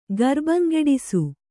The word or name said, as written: ಗರ್ಬಂಗೆಡಿಸು